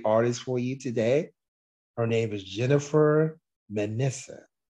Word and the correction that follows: Messina: Last name's pronounced Messina, Jennifer Messina.